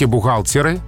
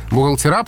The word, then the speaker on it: бухгалтеры: Вот в случае с бухгалтером все-таки бухгалтеры продолжают оставаться основным вариантом. Бухгалтера пока расценивается как просторечие.